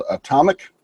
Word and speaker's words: Automic: And where my kind of claim to fame was, I came from a company called Atomic, that's spelled Automic for the phonetic spelling of it.